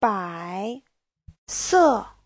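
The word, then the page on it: 白色: bai3se4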